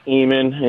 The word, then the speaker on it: Eamon: It's pronounced Eamon in Ireland, so get it right.